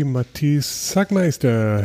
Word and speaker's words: Matic Zagmajster: I'm welcoming today Matisse Zagmajster.